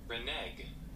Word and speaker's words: renege: I'm seeing how it's pronounced. – Renege.